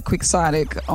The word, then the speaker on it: quixotic: It is Q-U-I. X-O-T-I-C. So if you see it in writing, you might not put that sound right to those letters, but that's what it is.